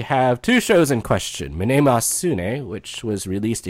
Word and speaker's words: Mnemosyne: Mnemosyne, folks. That's the official pronunciation. That's how it's said in Greek.